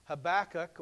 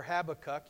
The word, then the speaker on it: Habakkuk: Most people looking at the book of Habakkuk, or Habakkuk, you can pronounce it either way, believe that the book was written sometime between 610 and 605 BC.